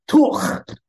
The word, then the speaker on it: Tor: And um, anyway, and and you know, and they pronounce my name in very different way. It's Tuch. You know, it's got a guttural sound, very very strong.